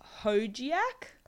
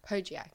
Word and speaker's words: Hojiak: And just at the entrance is a sort of quite well-known restaurant called Hojiak. – Hojiak, yeah. – Do you reckon, or...? – It's hard because when you say it in Malaysian, that's the correct way to say it, essentially. – True, okay. So we're going to... Anyway, the spelling is H-O-J-I-A-K, if you're looking it up.